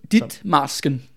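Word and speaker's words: Ditmarsken: heller aldrig hørt om det der Dickmarken. – Dittmarsken. Dit, Dittmarsken. – Ja, mener det udtales, ikke?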